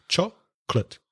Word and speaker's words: chocolate: Remember, chocolate has two syllables, not three, two syllables. Chocolate.